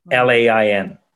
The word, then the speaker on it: Lauglan: But the real spelling of my middle name is Lauglan. That is the Scottish spelling. The way that I spell it is a little more Irish, but phonetically that's how people can pronounce it, because forever I've always got Laughlane or Loughlin.